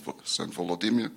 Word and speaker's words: Volodymyr: can be traced back to the baptism of Saint Vladimir, who in Ukrainian is pronounced as Saint Volodymyr.